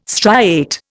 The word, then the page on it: striate: Indian